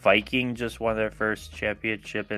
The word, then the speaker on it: Viking: I'm pretty sure if you want to pronounce Viking football club's name properly, it's Viking.